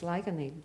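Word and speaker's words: Ligoniel: But the original name by locals is Laganeil. And it's Laganeil because it's the hollow of the limestone. So, of course, they're pronouncing it as the Irish was, which is so beautiful.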